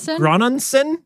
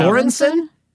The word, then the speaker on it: Göransson: Ludwig Göransson? – Yeah. – Göransson? – Right.